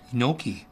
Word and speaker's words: gnocchi: Did you say gnocchi? – Gnocchi. Mm. Always don't pronounce the G. Gnocchi. Yeah. It's gnocchi.